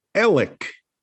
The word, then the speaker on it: Alex: the way you pronounce A-L-E-X is Alec. Alec. Not Alex, but Alec. So his first name is Alec.